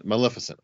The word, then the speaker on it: Maleficent: Maleficent.